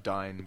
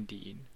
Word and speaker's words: Dyne: D Y N E. – Oh yeah, yeah. I pronounced it as Dine. – All my other friends called him Dine, but I always called him Dean.